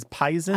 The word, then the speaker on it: poison: There's no etymology given in the English dialect dictionary, but one thing that occurred to me is there is the pronunciation of poison as as pison.